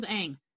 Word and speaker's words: Aang: In the cartoon, his name is Aang. – He says, my name is Aang. And I was like, I don't like this at all. And it just, everything's mispronounced.